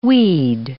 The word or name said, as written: weed